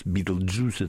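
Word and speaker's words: Betelgeuse: Где какие, кто там, Альфа Возничего, Альфа Ориона там и всякое, что такое Бетельгейзе по-русски, почему кино называется Битлджус, Битлджус это жучиный сок, но так произносится Бетельгейзе по-английски,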